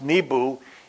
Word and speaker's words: Nabu: And her son was Nabu, N-A-B-U, the patron god of the scribes, literally, in literacy and in wisdom. Now, Bel Marduk and Nabu, or sometimes it's pronounced Nebu, E-N-B-O, is mentioned in the Bible.